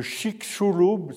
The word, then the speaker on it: Chicxulub: c'est-à-dire que vous avez tous entendu parler de la météorite de Chicxulub, c'est comme ça que ça se, ça se prononce, qui il y a 66 millions d'années est venue bouleverser l'évolution sur la Terre.